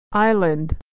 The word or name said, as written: island